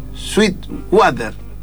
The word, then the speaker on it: Sweetwater: Sí, o Sweetwater, ¿cómo se dice, Mati? Sweetwater, así, muy bien.